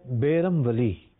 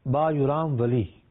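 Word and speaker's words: Bayram Veli: نام کے دو تلفظ ادا کیے جاتے ہیں، ایک ہے بیرم ولی اور دوسرا ہے با یورام ولی یا با یرام ولی۔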